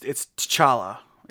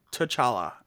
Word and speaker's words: T'Challa: Yes, it's T'Challa. Yes, say it that way. I don't like the way I say it, but yes, that's T'Challa is how it's pronounced.